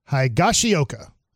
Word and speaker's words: Higashioka: Kyle Higashioka. Am I saying that right? – Higashioka.